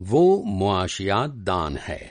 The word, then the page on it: मुआशियातदान: معاشیات داں